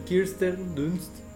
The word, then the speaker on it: Kirsten Dunst: Kirsten Dunst.